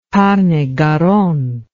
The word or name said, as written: tarn et garonne